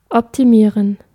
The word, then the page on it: optimize: American English; GenAm; /ˈɑptɪmaɪz/